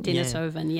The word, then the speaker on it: Denisovan: Did I get it quite right? – Yeah, no, you did quite Denisovan? – Well. – Denisovan? – Denisovan, yeah.